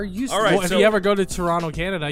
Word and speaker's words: Toronto: You like Toronto, you would know they don't pronounce the T in Toronto. It's Orano. No, it's just Toronto.